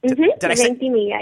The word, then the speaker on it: Ventimiglia: Ventimiglia, yep.